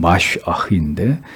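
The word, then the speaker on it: 마시아흐 (מָשִׁיחַ): 그리스도는 크리스토스, 히브리어로는 마시아흐, 우리가 아는 메시아, 원래 발음은 마시아흐인데 그 의미는 기름 부음을 받은 자라는 뜻입니다.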